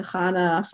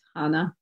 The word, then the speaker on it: Chana: And if people have a hard time saying my name, I will take the time to explain or just help them with a ch. But I also, will accept Hannah.